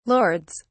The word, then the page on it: lords: British English